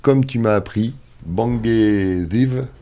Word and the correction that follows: Bangeziwe: c'est ça? – Bangeziwe.